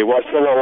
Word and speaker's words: Wasilla: Wasilla, Alaska.